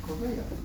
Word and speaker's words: Kovea: How do you pronounce the word? It's bare bones. – What does our Hebraic suggest? – Kuf Beis Ayin. Kova, Kova. Kova? – Kovea. – Kovea? Kovea, okay.